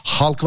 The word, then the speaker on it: Hulk: Hani herkes halk diyor adama ama elemana kendi filminde bile Hulk diyorlar. Hulk. Fakat ben yayında Hulk desem düzeltilir mesela. Abicim halk o şey diye okunuyor falan. ama aç filmi bak kendi filminde bile adamı Hulk diyorlar yani.